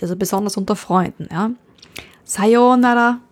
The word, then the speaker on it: Sayonara: Sayonara. Vorsicht bei der Aussprache, also nicht Sayonara, nicht auf dem Nara, sondern auf dem Sayo.